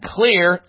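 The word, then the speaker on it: Xlear: Clear Max nasal spray and Clear, spelled with an X, X-L-E-A-R, X-L-E-A-R, Clear Rescue nasal spray.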